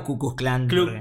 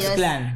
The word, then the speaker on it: Ku Klux Klan: KKK clan. KKK clan. – Digamos Ku Klux Klan. – Sí, pero también es difícil de pronunciar porque hay una L ahí, sí, entre ellos. Ku Klux Klan sería.